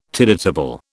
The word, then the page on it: tidetable: British English